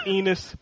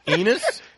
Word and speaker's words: Enos: I just like the name Enos. – Enos? – Only because it rhymes with penis, and he loves the penis.